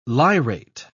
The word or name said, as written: lyrate